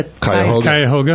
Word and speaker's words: Cuyahoga: When the, correct me on the spell, how do you pronounce that river? The Cuyahoga.